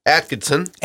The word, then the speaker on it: Atkinson: Eleanor Akinson. Eleanor Akinson. Am I pronouncing that right? – Atkinson. – Atkinson. – Yeah. Eleanor Atkinson, I would pronounce that.